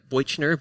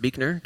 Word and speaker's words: Buechner: Looks like Boichner, but it's pronounced Buechner, just FYI.